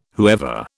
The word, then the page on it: whoever: British English